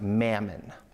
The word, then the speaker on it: mammon: Now, this is an Aramaic word pronounced mammon.